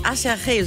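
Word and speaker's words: Aserejé: Song von einer Combo namens Acherche, so wird die ausgesprochen, aber es sieht aus wie Ach herrje, so kann man sich das auf jeden Fall gut merken.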